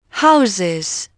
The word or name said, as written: houses